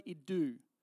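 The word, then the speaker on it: idou (ἰδού): This is important, and it's the word idou. If you were to transliterate it into English, you'd spell it I-D-O-U, idou, right?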